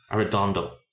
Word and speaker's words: Arredondo: Let me see if I pronounce this correctly. Arredondo. Arredondo. Yeah, that's it.